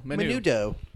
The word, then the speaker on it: menudo: Is it menu, though? – Yeah, menudo.